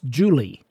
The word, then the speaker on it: Jule: S-T-Y-N-E and his first name to J-U-L-E, pronounced Julie.